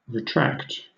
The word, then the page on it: retract: British English